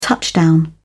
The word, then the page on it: touchdown: British English